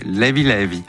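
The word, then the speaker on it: Léve Léve: DJ Tombé est notre invité pour la sortie du volume 2 de ses compilations sur Sao Tomé et Principe, qui s'appelle, moi je dis Lévé Lévé, et ça se prononce pas comme ça. Comment ils disent là-bas? – Lévi Lévi. – Lévi Lévi. Très bien.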